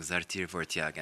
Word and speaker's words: Zartir Vortiag: So tell us about this song, Zartir Vortiag, and tell me first if I pronounced it properly. – No, you did it just like an Armenian.